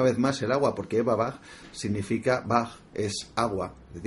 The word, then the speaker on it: Eberbach: Y sí, nazco en un pueblo que se llama Eberbach, se escribe Eberbach, se pronuncia Eberbach, y que, una vez más, me voy...